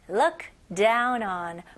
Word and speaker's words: look down on: 여기서는 look이 아니죠? 네, 으 발음에 더 가까워요. look. 그 다음에 다운이 아니고 이름절로 down. down. 그 다음에 온이 아니고요. 입술이 동그래지면 안 됩니다. 이건 한국식 발음이에요. 네, 영어는 on, 턱이 내려가는 느낌입니다.